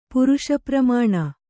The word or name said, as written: ಪುರುಷಪ್ರಮಾಣ